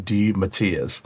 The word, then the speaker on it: DeMatteis: I actually heard somebody pronounce that. So okay. It's J.M. DeMatteis.